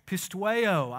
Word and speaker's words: pisteuo: Pisteuo, I believe is how you pronounce it. P-I-S-T-E-U-O is the Greek word.